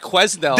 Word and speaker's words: Quesnel: It's the way I was gonna call it Quesnel. – That's what it is. – Yeah. – There's a silent S in there.